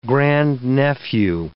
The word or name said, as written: grandnephew